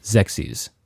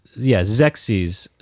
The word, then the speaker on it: Xexyz: I think that's how you pronounce it. Right. Right. Zexies. It's spelled Xexyz.